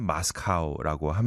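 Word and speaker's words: Moskau: In German, 모스크바를 이제 German으로 읽으면 마스카우라고 합니다. 징기스칸의 마스카우라는 곡인데요. 아마 들어보시면은 아, 그곡 아실 만한 그런 곡입니다. 마스카우로 가보겠습니다.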